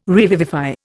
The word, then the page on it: revivify: Indian